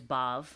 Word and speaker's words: Badb: There's Maka, there's Bav, which is spelled B-A-D-B. Those of you who have seen my book Maeve will recognize this Irish spelling.